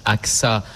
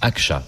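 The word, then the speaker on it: Ağca: Alors, ça se prononce AXA en français, mais en turc, Akça, puisqu'il était turc.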